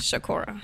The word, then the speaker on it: ShaCora: So, Shakura, correct? Did I say that right? – Yes, Shakura, okay. – Like Tupac. Tupac Shakur, my name is Shakura.